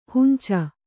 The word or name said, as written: ಹಂಚು